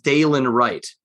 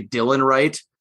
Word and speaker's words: Dalen Wright: it's pronounced Dalen Wright. I'm like, oh, thank you. You're going to save me so much time, because I was going to say Dylan Wright.